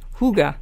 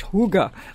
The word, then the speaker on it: hygge: And don't forget, people, it's spelled, The Higgy Life is the name of the book, and it's spelled H Y G G E, but you pronounce it um, in the language Huga you know, H O O G U H, Huga, like that.